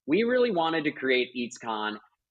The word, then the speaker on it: EEEEEATSCON: I'm going to pronounce it EEEEATSCON. Did I do that correctly? – You did it great. Honestly, you nailed it. You can also say EEEEATSCON, and people would be fine too.